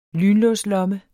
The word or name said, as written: lynlåslomme